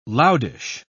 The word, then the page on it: loudish: British English